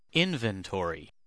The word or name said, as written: inventory